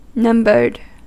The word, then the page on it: numbered: /ˈnʌm.bɚd/; /ˈnʌm.bəd/